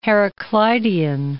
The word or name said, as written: Heraclitean